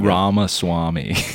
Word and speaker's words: Ramaswamy: I don't know exactly where Ramaswamy, probably said it wrong. – Ramaswamy. – Ramaswamy. – Like ramen. Like ramen noodles.